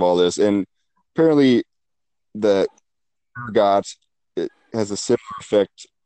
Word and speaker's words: ergot: And one of the popular explanations is something called is it ergot? I want to call it ergo, but that's not the pronunciation. It's ergot.